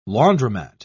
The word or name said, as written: laundromat